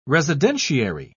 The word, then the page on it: residentiary: British English